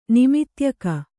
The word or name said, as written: ನಿಮಿತ್ಯಕ